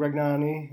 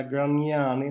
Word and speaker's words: Gragnani: Tom Nilsson, Linus Arnesson, Greg Nanny, eller om man nu ska heta Gragnani med italiensk uttal.